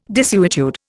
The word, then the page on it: desuetude: Indian